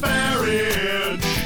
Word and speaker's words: Farage: It's pronounced Farage. It's spelled like it sounds. It rhymes with same-sex marriage.